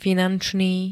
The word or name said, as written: finančný